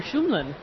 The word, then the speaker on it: Shumlin: Governor Peter Shumlin.